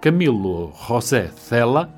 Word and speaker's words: Camilo José Cela: Camilo José Cela, era assim que ele gostava que lhe dissessem e pronunciassem o nome,